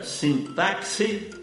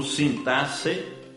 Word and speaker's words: sintaxe: Você pronuncia sintaxe ou sintaxe? Que eu saiba, apenas o Aurélio abona ambas as formas. Prefiro acompanhar o Ais, Caldas Raulete e o Volpe, que registram apenas a pronúncia sintaxe.